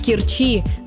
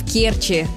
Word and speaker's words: Керчи: Нередко можно услышать вариант «в Керчи», но это ошибка. Правильно – в Керчи, с ударением на первый слог.